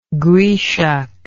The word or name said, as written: grieshoch